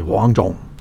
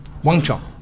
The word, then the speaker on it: Wang Chung: And I don't think I'm going to get the name right, but it's Hung Chung or Hong Chung? – Sort of, yeah. Huang. In Chinese, you sort of say Wang Chung. – Wang Chung.